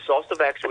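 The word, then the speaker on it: Zostavax: I mean, there's Shingrix, I'm not sure if I'm pronouncing it properly, and Sostavax, is that correct? – Indeed, yes. There's the Sostavax, which is the older version, which is the live attenuated vaccine.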